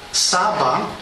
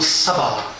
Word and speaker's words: Sabá: Sába, tedy správně vysloveno Sabá, královna Belkis nebyla ze Sáby, ale byla ze Sabá.